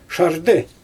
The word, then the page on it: ʃardɯ: Xyzyl